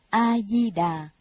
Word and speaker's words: A-di-đà: Người Việt ta cứ theo lệ phổ thông, chữ đâu đọc đó, nên đọc là A-di-đà.